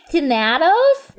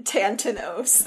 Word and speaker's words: Thanatos: Find Tanthan- it's Tanatos. Yep. – You've been letting me say it wrong the whole time.